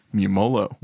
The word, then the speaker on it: Mumolo: And Barb and Star are played by Kristen Wiig and Annie, how do you say her last name? Mernolo? – Mumolo.